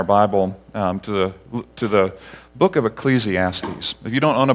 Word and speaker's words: Ecclesiastes: but is Ecclesiastes.